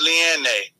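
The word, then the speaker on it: Liene: Her name is Leanne. – Leanne.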